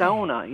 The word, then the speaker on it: sauna: Up in the UP, however, historically, in terms of the literature, people have suggested strongly that the only way you can say it up in the UP is sauna. – Sauna, okay. – Sauna, yeah, ow. It's the ow. That first syllable is really, that first vowel in that syllable is really what you're after, and it's sauna.